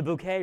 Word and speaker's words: Bouquet: But as we began our evening with Hyacinth Bouquet, and that's spelled B-U-C-K-E-T, by the way, bucket. But it's not Bucket. It is the Bouquet residence.